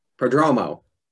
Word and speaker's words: Prodromou: Did I say your last name right? – Prodromou. Very close. You're very close.